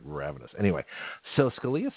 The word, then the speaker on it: Scalia: So Scalia, who I mispronounced earlier, Scalini, that was pretty good, actually.